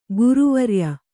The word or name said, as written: ಗುರುವರ್ಯೆ